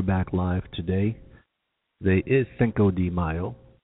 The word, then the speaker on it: Cinco de Mayo: Am I de. – De. – Cinco de Mayo. – Cinco de Mayo. There you go. – Even though it's spelled D E.